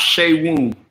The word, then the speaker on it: Seun: No, it's pronounced Seun.